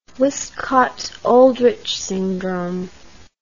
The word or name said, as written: wiskott aldrich syndrome